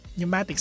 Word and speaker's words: pneumatic: Okay, pneumatic, silent P kasi yun, P-N-E-U-M-A-T-I-C.